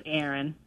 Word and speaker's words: Erin: Oh, my name's Aaron. – Aaron. – Spell that. – E-R-I-N.